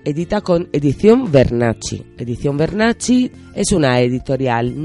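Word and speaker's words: Vernacci: Bernacci, esta vez mira cómo lo pronuncio muy bien. Bernacci está escrito en italiano con V de Valeria y con doble C.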